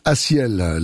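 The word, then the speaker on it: A6EL: Ah, plus on l'écoute et plus on la découvre, cette chanson de cet artiste suisse qui s'appelle Asielle, et je le dis à chaque fois, si vous le cherchez, hein, ça s'écrit A, le chiffre 6, E-L, Asielle, laisse-le rêver.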